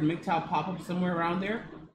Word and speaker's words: MGTOW: And one radical fringe that is growing is MGTOW, which stands for Men Going Their Own Way, and pronounced MGTOW.